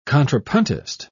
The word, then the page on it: contrapuntist: British English